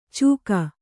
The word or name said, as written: ಚೂಕ